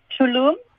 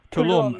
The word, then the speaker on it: Tulum: And North America, Is it, how's it pronounced? Is it Tulum, Mexico? – Tulum. – Tulum, Mexico.